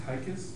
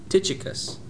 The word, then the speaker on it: Tychicus: Is it pronounced Tychus? – Tychicus.